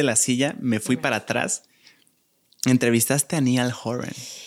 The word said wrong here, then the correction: Niall: ¿Cómo se dice? Niall. – Sí, Niall. Niall, no Niall, esto se repite.